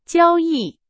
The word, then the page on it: 交易: Chinese